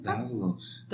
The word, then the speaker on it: Davalos: Is it Davalos? – Davalos. – The right pronunciation? – Yes.